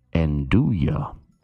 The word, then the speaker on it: nduja: The correct pronunciation is Andouille. Andouille. – Okay. This is a spicy pork sausage. In the pork family. Here it comes. Handing her the sausage. – Okay. N-D-U-J-A. – Yes, that's how it's spelled. N-D-U-J-A.